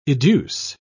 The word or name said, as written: educe